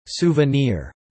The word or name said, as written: souvenir